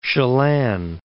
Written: Chelan, Lake

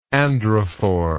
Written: androphore

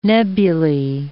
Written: nebulae

